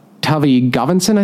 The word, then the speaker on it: Tavi Gevinson: Um, and then in an entirely different section, uh, Tavi Gevinson, I think you say her name. – Yeah. Um, who I mainly know as an actress, but apparently like a huge fashion designer.